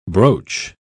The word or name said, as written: brooch